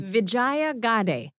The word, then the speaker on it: Vijaya Gadde: Vijaya Gadde.